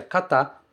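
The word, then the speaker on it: kata (方): Esse kanji que tá aí, ele se pronuncia kata, muitas vezes, para significar pessoa, de uma maneira mais formal.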